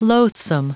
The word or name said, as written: loathsome